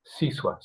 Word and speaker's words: Siswa: giving us an indication that his hypocristicon would be pronounced something like Siswa.